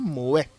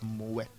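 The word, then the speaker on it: Moët: So it's originally Dutch, but Moet. Moved to France. – But the French say Moet. – Yeah, the French will say, but you know, if you look at the Dutch pronunciation, it's Moet. – So which one? Which is which? – The more popular one is Moe. – So if I go with the Moe.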